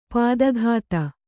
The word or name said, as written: ಪದಾಘಾತ